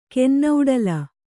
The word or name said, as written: ಕೆನ್ನೌಡಲ